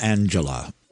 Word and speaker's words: Angelia: Apparently it's pronounced Angela, but spelt Angelia.